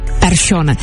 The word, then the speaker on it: Persson: Z Larsona je najednou Larsson a z Persona Persson.